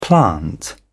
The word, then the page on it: plant: British English